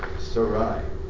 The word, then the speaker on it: Sarai: I didn't realize that before Sarah had her name changed, that the correct pronunciation was Sarai, or Sarai. Nathan and Ashley probably knew that. Because you just add the at the end and you've got it.